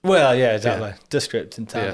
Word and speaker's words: Descript: Descript, I think. – I say Descript.